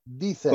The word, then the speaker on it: Deezer: Wow, eh, vamos a decirle que Deezer se escribe, se escribe, escúchame a mí como estoy diciendo, se escribe D-E-Z-E-R, Deezer.